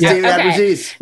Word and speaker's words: Abbruzzese: Yes, it's David Abbruzzese.